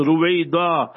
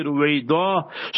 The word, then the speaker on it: Ruwaydha: So therefore, if you're spelling it and pronouncing it correctly, and that is how it's supposed to be pronounced, Ruwayda, R-U-W-A-Y-D-H-A, Ruwayda.